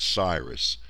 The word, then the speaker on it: Cyrus: They didn't have a soft C back then. They were probably both Cyrus and Cambyses, but everyone says Cyrus.